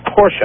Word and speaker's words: Porsche: It's Porsche. I actually used to work for them. It's a two-syllable word.